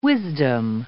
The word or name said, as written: wisdom